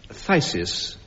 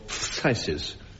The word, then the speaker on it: phthisis: Anne Scott James, phthisis or phthisis... P-H-T-H-I-S-I-S. You can pronounce it either way.